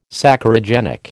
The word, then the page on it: Saccharogenic: American English